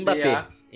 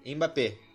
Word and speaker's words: Mbappé: Mbappé. – Mbappé.